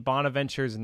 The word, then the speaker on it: Bonaventure: Saint Bonaventure Bonnies? – Yep. Bonaventure Bonnies from New York.